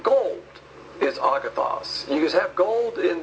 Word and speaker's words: agathos: It should be A-G-A-T-H-O-S, not A-S. It's pronounced agathos.